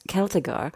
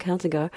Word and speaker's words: Celtigar: Also es ist mit C vorne geschrieben, ich glaube, es wird mit K ausgesprochen, Celtigar.